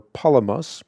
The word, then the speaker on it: Palamas: In fact, yet I wanted to get to this before the broadcast ended last night, Palamas, or Palamas, more correctly pronounced, offers the vivid illustration of a pot in a kiln.